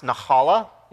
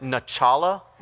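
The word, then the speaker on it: nahala (נחלה): It's pronounced nahala. You have to kind of get that like sound. In Hebrew class when I was in seminary, I pronounced it nachala one time because I think I just love nachos and I think I just read it that way.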